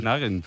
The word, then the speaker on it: Nugent: Claro, yo era de Ted Nugent.